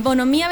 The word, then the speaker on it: Bonhomía: El Bonomía, a veces la gente se confunde un poquito, pero tienen que pensar que Bonomía viene de la palabra buen hombre. – Entonces se escribe Bon... – Bon o Mía. – O Mía. – Claro, entonces hay una H ahí.